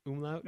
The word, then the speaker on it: umlaut: Umlaut?